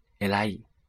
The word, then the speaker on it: 偉い: 但是呢，这个伟是繁体的伟，而且伟大这也是个多项形容词，既可以形容事物，也可以来形容人，请记住 elai。